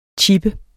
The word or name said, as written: chippe